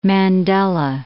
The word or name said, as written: Mandela